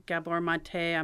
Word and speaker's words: Gabor Maté: So a minute ago, you mentioned trauma-informed services, and I know that there are some providers like Gabor Maté.